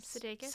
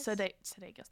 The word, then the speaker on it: Sudeikis: Sudeikis? – Sudeikis, thank you.